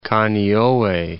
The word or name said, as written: Kaneohe